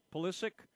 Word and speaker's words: Pulisic: Pulisic, and Kellen Acosta have come on now for Tim Weah and Yunus Musa.